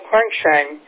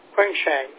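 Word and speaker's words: Quansheng: Okay, I'll have to spell it to you, but it's called Quangshang. I think it's a Chinese radio. It was given to me a month ago as a gift, and it's spelled Quebec Uniform Alpha November Sierra November Echo November Gulf. I'll say it again. It's Quebec Uniform Alpha, November Sierra Hotel Echo, November Golf. Quingshang, over.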